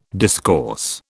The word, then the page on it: discourse: British English